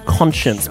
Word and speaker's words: Konshens: My favourite thing about this is the rap sort of moment featured on this is by someone called Conscience, but it's spelled K-O-N-S-H-E-N-S.